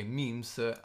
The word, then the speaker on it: meme: Diciamo che abbiamo capito, secondo la rivista Wired, che si pronuncia meme. – Wired. – Wired, sì, esatto. Si pronuncia meme al singolare e memes al Memes.